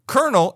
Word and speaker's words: colonel: We borrowed it from the French originally, and they said... Coronel, and we said Coronel, and then we shortened it to Cornell, Colonel, Coronel sure.